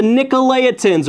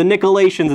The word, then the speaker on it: Nicolaitans: It says, "But this thou hast, thou hatest the deeds of the Nicolaitans," or the Nicolaitans, it's also been pronounced too, "which I also hate."